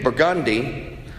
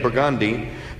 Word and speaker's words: Burgundy: How do you say Burgundy Street? Burgundy. I don't know how we got Burgundy out of Burgundy, but it's Burgundy.